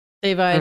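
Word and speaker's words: Da'Vine: But how do you pronounce her? Davine? – Davine, mm-hmm. Yeah.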